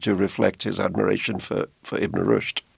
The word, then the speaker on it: Ibn Rushd: The story actually begins, eight centuries ago with a 12th century philosopher named, I hope I pronounced this correctly, Ibn Rushd.